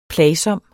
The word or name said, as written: plagsom